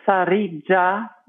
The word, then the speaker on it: Sarica: Ja, ja, also erstmal der Name Sarija mit einem rollenden R und einem J wie Dschungel in der Mitte.